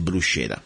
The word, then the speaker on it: bruschetta: Probably in English you say bruschetta, okay? I know that when I go to America, you say bruschetta. I want to tell you exactly what how to say it in Italian. It's called bruschetta. And let me spell it for you. B-R-U-S-C-H-E-T-T-A, okay?